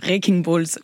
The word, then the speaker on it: Wrecking Ball: C'était Rocking Ball. J'ai très mal prononcé. Wrecking Balls.